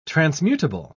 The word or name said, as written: transmutable